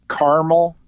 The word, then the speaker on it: Carmel: At the top of the list, Carmel, Indiana. And yes, that's how you say it. Some people will confuse this city with Carmel in California, but this is Carmel, like the ice cream topping, Indiana.